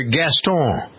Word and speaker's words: Gaston: The character Gaston, who's from Beauty and the Beast.